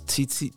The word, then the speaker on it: Titi: Yes, that's Titi, T-I-T-I, the same way you pronounce. – Yeah. – T-I-T-I. Woman's titties. Same pronunciation, so it's Titi.